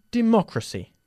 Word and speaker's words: democracy: And democracy. – Democracia. – ¿Dónde está el énfasis ahí? Democracy, ¿ok? En la segunda sílaba.